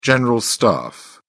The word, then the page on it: general staff: British English